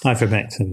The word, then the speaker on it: ivermectin: Ivermectin, yeah.